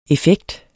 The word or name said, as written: effekt